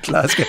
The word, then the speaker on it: Glasgow: Glasgow. That's how you say it. Listen, this is how Joe Biden says it. Are you listening? – Glasgow.